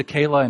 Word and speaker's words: Keilah: By the way, Keilah spelled K-E-I-L-A-H. I think I spent the last sermon in 1 Samuel 23 several weeks ago now, a couple months ago, pronouncing it Keilah.